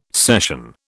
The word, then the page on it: session: British English